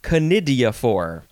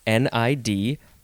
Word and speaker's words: conidiophore: The first word is conidiophore, and it is spelled C O conidiophore, conidiophore.